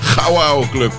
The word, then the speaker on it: Gauwauke: Oh, echt Gauwauke Club. Zeg ik het goed? Ja, dat zeg ik helemaal goed.